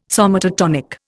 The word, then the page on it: somatotonic: Indian